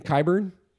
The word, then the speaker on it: Qyburn: Qyburn.